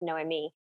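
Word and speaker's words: Noemí: And I want to make sure I pronounce her name right, because in Spanish it's Noemi.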